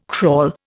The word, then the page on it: crawl: Indian